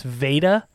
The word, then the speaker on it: Veda: And there was a character, a counselor, played by I keep pronouncing it with a B, which is wrong. Not Beta, it's Veda.